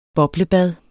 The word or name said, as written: BOBLEBADET